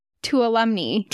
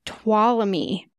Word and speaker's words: Tuolumne: Because I always said Tuolumne. – Tuolumne Meadows. – Because that's how it's spelled. That's what it looks like. But apparently it's Tuolumne. You don't even say the N.